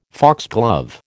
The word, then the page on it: foxglove: American English